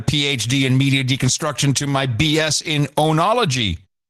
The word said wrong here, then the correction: enology: Is that how you pronounce it? Onology? What is onology? – I have no idea what onology is. – There's got to be something good. – Oh, enology.